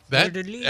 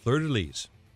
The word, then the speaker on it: fleur-de-lis: It's a purple hat with gold fleur-de-lis. Is that how you say it? – Fleur-de-lis. Excellent. – Fleur-de-lis.